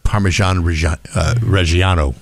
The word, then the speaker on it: Parmigiano Reggiano: Parmigiano Reggiano.